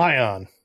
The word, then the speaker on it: PLAION: Play On.